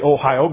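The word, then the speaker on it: Ohio (おはよう): Or if it's someone like a pastor or a mayor or somebody that you want to be especially honoring, they have another term, Ohio, spelled differently, but it's pronounced the same as our state. If you want to make it honorific, you say Ohio gozaimasu.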